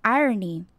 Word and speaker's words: irony: 接下来，some irony。 Irony 这个字比较难念，在发音的时候要注意 R 这个字的卷舌音不要太强调，念 O 的时候嘴巴还是要维持比较开，不要变成 O 的音。